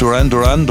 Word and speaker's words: Duran Duran: Die nächste Band, die kann man eigentlich fast so aussprechen, wie sie geschrieben wird. Duran Duran, Duran Duran, das ist Deutsch-Englisch immer das Gleiche.